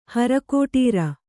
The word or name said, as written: ಹರಕೋಟೀರ